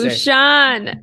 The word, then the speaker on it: Bhushan: Bhushan.